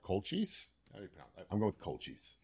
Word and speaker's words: Colchis: it's, Col- it's Colchis. Colchis is such a better way of saying it. Anyway, Colchis. Anyway, the H is silent because English is a fun language.